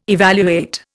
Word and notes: evaluate: Indian

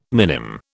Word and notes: minim: British English

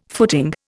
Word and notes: footing: Indian

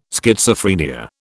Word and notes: Schizophrenia: British English

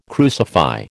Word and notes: crucify: American English

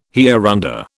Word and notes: hereunder: British English

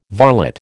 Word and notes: varlet: American English

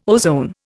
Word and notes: ozone: Indian

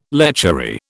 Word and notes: lechery: British English

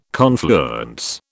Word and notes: confluence: British English

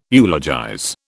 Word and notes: eulogize: British English

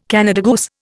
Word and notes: Canada goose: Indian

